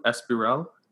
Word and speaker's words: Espiral: Is it pronounced Espiral? – That's correct.